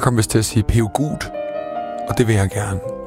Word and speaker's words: Peugeot: Jeg fik sagt Peugeot, det hedder det ikke, det hedder Peugeot. Pø som i pølse, cho som i chokolade, Peugeot.